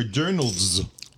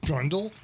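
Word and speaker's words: dirndl: How do you pronounce it? – Or dirndls. – Dirndls? – Dirndls. – Dirndls. Dirndls. – Drundle? – Dirndls. – Dirndls? – Very good. Dirndls.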